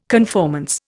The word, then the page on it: conformance: Indian